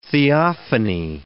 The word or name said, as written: theophany